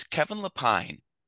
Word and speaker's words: Kevin Lepine: You're not the first or only person to mispronounce my last name. – Uh-oh. How do you say it? – It's okay. Kevin. It's Kevin Lepine.